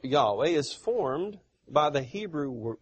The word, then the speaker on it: Yahweh: They prefer to use the word pronunciation Yahweh.